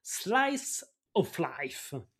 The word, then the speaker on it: Slice of Life: Alors avant de replonger dans la routine, je vous propose un gyoza spécial Slice of Life. Je l'ai bien prononcé là, imagine. – L'accent. – Magnifique, super.